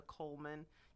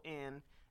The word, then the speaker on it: Colemon: If you put my name in the Google or go to Amazon, type in Mia Colemon. You know, my last name is spelled funny. Make yes. – sure you type Colemon, and it'll pop right up.